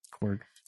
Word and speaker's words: Korg: Carlo Pagulayan from Planet Scar, including Krog. – Korg. – Fun fact, Korg. See, I always say his name wrong.